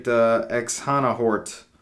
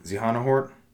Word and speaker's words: Xehanort: I think they pronounce the X as in Latin for Z's again. So. – Xehanort?